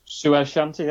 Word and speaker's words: Suez Shanty: Said that right? – Suez Shanty, yes.